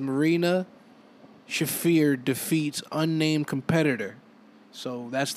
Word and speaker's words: Shafir: Marina Sheriff? – Marina Shafir. – Shafir? Shafir. Let me make sure. How you spell that? – S-H-A-F-I-R.